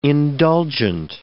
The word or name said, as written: indulgent